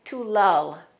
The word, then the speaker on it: lull: Let's make sure we're pronouncing that vowel sound correctly. It's the same sound as in the word dull. It's different from the word pull. That's more like this, pull. This is to lull.